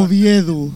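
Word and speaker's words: Uviéu: que era un local que había en Oviedo en la época de los 80. – Oviedo no, perdón. Oviedu. – Oviedu, es verdad, Oviedu.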